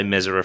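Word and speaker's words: Mezera: Thomas Mesera, and I will say Mesera from now on because that's how you say his name.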